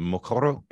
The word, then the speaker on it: Mokoro: So it's called the Mokoro experience. Am I pronouncing that correctly? Yeah.